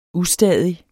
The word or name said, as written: ustadig